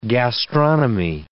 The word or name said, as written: gastronomy